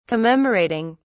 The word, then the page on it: commemorating: British English